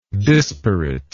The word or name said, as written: disparateness